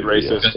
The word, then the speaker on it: Villa: you wouldn't pronounce the L's, so this I'm going to say David Villa is what it is.